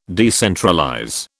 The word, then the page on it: decentralize: British English